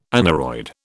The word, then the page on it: aneroid: British English